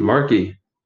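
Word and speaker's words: Markey: Marky. – Marky or yeah, Markley? – Marky, like Marky. Marky Mark.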